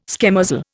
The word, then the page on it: schemozzle: Indian